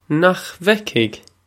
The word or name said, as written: Nach bhfeicfidh